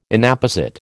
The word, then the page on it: Inapposite: American English